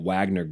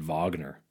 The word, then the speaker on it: Wagner: Like, I was reading it Wagner Group, and then I hear it on the news. They're calling it Wagner. Why not just spell it that way?